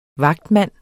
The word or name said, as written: vagtmand